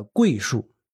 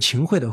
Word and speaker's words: 桧: 这个园子里呢有两棵巨大的桂树啊，桂是一个木字旁一个开会的会，就是秦桧的会，它是个多音字。